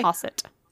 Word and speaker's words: Ossett: Osset. O-S-S-E-T-T.